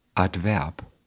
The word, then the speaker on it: Adverb: Also im Deutschen werden mehrsilbige Substantive, die aus dem Lateinischen kommen, meistens auf der letzten Silbe betont. Zum Beispiel Büro, Fabrik, Harmonie oder auch Adverb.